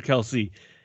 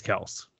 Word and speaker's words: Kelce: And then you have to look at Travis Kelce, yes, there's been a lot of controversy this week. Is it Kelce or Kelsey? And Jason finally went on Twitter today and said, it is Kelce.